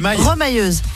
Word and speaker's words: remailleuse: Est rémailleuse. C'est ce que c'est Rémailleuse. – Remailleuse. – On dit, ça se prononce pas comme ça? Remailleuse? – Remailleuse, oui. – Ah, remailleuse, pardon.